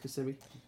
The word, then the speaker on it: Kissimmee: Is it Kissimmee? Did I say it wrong? – Yeah. – I said Orlando. How do you say? No, it's Kissimmee.